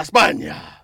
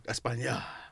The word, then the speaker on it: España: Let's go over to La España. That's how you say it. – España.